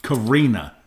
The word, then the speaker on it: Carina: You know, my very first car was a Toyota Carina Deluxe. I said that right. Carina.